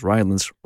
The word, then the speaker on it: Rylance: I'm not going to give too much away, but let's just say they could call it A History of Rylance, because Rylance rhymes with violence.